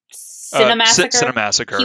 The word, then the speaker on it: Cinemassacre: It's Cinemassacre.